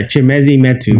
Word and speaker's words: Chimezie Metu: And you also have Chizé Metui or Metu. Mm-hmm. I don't know how to say his name. – Yeah, Chimezie Metu.